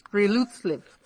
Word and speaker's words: friluftsliv: It was ruining my friluftsliv.